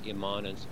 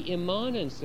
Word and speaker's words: immanence: I pronounce immanence oddly. Many people say immanence, and I don't need to explain why I pronounce it oddly, but it has an A in it, I-M-M-A-N-E-N-C-E, okay?